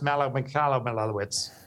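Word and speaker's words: Michalowicz: You nailed it. It is pronounced Mikalowicz. That is the name.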